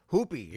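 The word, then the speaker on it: Hoopi (Hoʻopiʻi): Then Sol Hoopi, his name is spelled H-O-O-P-I-I. You see, the second I is silent.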